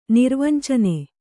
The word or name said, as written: ನಿರ್ವಂಚನೆ